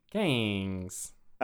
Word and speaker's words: Gayngs: G-A-Y-N-G-S? – Gangs.